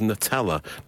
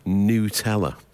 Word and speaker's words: Nutella: calls Nutella Nutella. And he says that's how they pronounce it in Italy, wherever it's made. I think it was Italy. – Nutella. – Nutella. And they do, on the continent, call it Nutella.